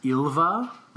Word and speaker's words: Ylva: and it's pronounced Ylva, like Y-L-V-A, and I guess I can read this tagline.